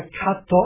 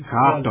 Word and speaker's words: kato: Der Akzent fällt immer auf die vorletzte Silbe. – Na, das ist ja ein Ding. – Ja, oder auch gesagt, die Betonung. – Ja. Die Betonung. Ja. – Bei Kato, also das A betont, weil das die Kato. – Vorletzte ist. Oder bei Elefanto ebenfalls, das A, weil es auch die vorletzte ist.